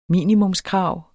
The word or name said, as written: minimumskrav